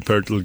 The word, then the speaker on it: Pöltl: Und dann hat sich halt auch ein, ein Basketball-Wordle-Spiel entwickelt und das wurde dann Wordle Purtle, wie, wie Purtle gern ausgesprochen wird im Englischen, ähm, nach mir benannt.